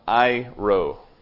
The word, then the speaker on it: airo: And it's a word that's pronounced airo. A-I-R-O. It's Greek.